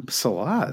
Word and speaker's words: Salah: Yep, Salah.